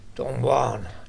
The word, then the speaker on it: Don Juan: I can never pronounce the J's as H's like they do in the Spanish countries. – Don Juan.